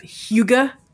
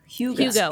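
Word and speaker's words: hygge: Hyuga. – Hyuga. – You pronounce it Hyuga.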